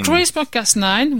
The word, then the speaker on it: CRISPR-Cas9: CRISPR-Cas9, voilà.